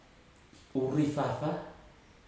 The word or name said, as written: urifafa